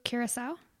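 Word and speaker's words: Curaçao: Uh, blue curacao.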